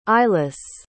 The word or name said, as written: eilis